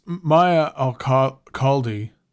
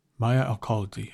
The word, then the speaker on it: Maya Al-Khaldi: So this Maya Al-Khaldi, Maya Alcaldi, I think that's how it's pronounced, album is unbelievable.